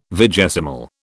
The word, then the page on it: vigesimal: British English